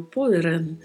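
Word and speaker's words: podrán: A pronúncia, nós temos que ter atenção com a letra O, que é mais fechada, e com o som da letra D, que não é um som muito marcado, Poran.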